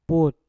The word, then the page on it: put: mpur